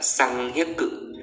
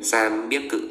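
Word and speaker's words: sam biếc cự (三百): bình thường nếu như không biên âm sẽ là xăng hiếc cự đúng không thế nhưng mà người nhật người ta sẽ không đọc là xăng hiếc cự mà người ta sẽ đọc là sam biếc cự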